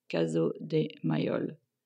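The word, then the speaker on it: Casot des Mailloles: Caso de Mayol, so C-A-S-O-T, and then D-D-S, and then Mayol is M-A-I-L-L-O-L-E-S. Caso de Mayol.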